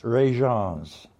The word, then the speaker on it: Rejans: R-E-J-A-N-S, Regence.